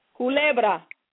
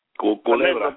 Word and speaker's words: Culebra: And tell me if I'm pronouncing this right. I believe Culebra, Puerto Rico. – Culebra. – Culebra, Puerto Rico. – Yes.